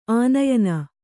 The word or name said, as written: ಆನಯನ